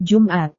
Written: jumat

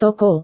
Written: toko

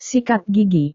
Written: sikat gigi

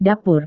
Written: dapur

